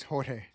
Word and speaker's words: Jorge: Is it Jorge? Jorge? How do you say your first name? – It's Jorge.